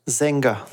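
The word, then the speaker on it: Sänger: A i osud, osud toho pána, který se jmenoval Eugen Sanger, čte se Sanger. Zenger.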